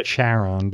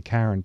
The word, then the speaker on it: Charon: It's spelled Charon. People would say Charon, but it's really Charon, pronounced Charon.